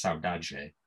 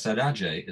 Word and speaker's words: saudade: And there's this concept in Brazilian music called, I'm not sure I can pronounce this word properly, but I think it's pronounced saudade, S-A-U-D-A-D-E.